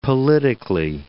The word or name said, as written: politically